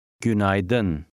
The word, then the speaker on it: Günaydın: The AY combination in the middle has an I sound. – Günaydın.